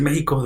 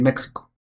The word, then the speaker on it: México: Y de hecho no deberíamos decir México, deberíamos decir México.